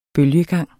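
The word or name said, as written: BØLGEGANGE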